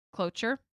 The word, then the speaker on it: cloture: also known as cloture. Cloture? – Cloture. – I said that right.